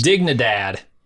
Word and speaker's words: Dignidad: Dignidad? – No, man. That's a hard G. – Dig. – In Spanish. – Dignidad.